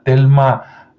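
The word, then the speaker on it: Thelma: Busca a Telma eh, Moss, se escribe con H, e T-H-E-L-M-A, Telma, y Moss se escribe M-O-S-S.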